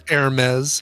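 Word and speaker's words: Hermès: It's pronounced Hermes. – Is it Hermes? It's not Hermes. I want it to be Hermes. – It's not Hermes. No, it's Hermes. – Even though that spells Hermes? – Even though it's spelled Hermes, yeah.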